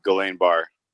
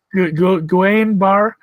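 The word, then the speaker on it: Guillain-Barré: Guillaume Bear. Yeah. – Guillain-Barre.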